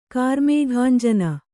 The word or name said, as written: ಕಾರ್ಮೇಘಾಂಜನ